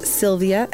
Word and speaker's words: Silvia: I am at Sylvia, S-I-L-V-I-A, 717.